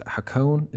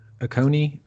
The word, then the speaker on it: Hakone: Hakone? Is that it? Hakone? Or I think so. – Okay. Hakone.